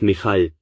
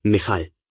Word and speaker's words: Michal: Wie würdet ihr ihren Namen aussprechen? Ich weiß nicht, wie es im Englischen ausgesprochen wird, also sage ich einfach Michal, wie das Hebräische, Michal.